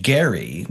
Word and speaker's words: Gerry: And it takes its name from Elbridge Gerry with a hard G.